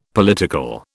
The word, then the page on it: political: British English